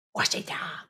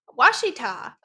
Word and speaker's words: Ouachita: It's like we have the Ouachita Mountains. It's pronounced Washita. What? Washita. Washita. Okay, Washita Mountains.